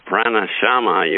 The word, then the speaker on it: pranayama: Yeah, well, you've invented a special form of yoga called pranayama yoga. I'm not sure I pronounced it right. – Yeah, you said it right. That was right.